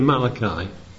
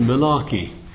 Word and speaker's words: Malachi: Let's turn over to Malachi. Turn over to Malachi. Well, as in Kenya, they pronounce it Malarkey, which means something different over in our neck of the woods.